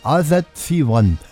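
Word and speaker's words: All That She Wants: Ist das richtig ausgesprochen? Der Gernot. All That. Doch? All That. Das ist ja viel Ich, das muss ja lispelt werden. All That She Won.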